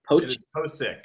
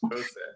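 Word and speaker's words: Pocic: Is left guard his what's the long-term goal with Posick, or how do you say his name? – Posick. I've got confirmation. Yes, Posick.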